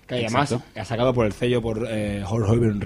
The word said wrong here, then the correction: Outer Heaven: Records, que no sé si lo pronuncio mal. – Outer Heaven, ¿ves? Outer Heaven Records